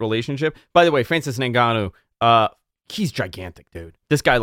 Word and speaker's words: Ngannou: What if Brock loses the title at the Royal Rumble or Saudi Arabia, and WWE builds towards a match with Francis Ngannou? Am I saying that right? – Ngannou.